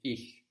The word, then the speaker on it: ich: It's ich, soft.